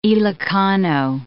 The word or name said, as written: Ilokano